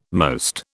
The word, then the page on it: most: British English